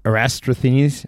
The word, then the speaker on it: Eratosthenes: Eratosthenes, whatever his name is.